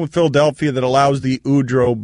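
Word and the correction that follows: Udrih: buyout to, I know I always mispronounce Udry.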